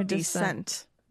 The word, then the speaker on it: descent: You pronounce descent in an odd way. – Oh, my descent. My descent. – Yes. – Oh, I read it as decent.